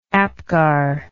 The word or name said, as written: Apgar